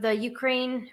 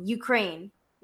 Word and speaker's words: Ukraine: I guess it's still like serious news, but For people that don't know, the Ukraine, or I mean, sorry, I just said it wrong. Ukraine. If you say the Ukraine, that's offensive. So it's just Ukraine.